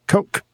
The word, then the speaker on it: Koch: Marita Koch, coach. How do you say her name, John? Coke, according to John.